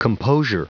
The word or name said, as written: composure